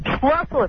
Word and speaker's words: Schlossel: Schlossel. It rhymes with muscle.